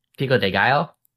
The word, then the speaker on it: pico de gallo: Oh, pico de gallo.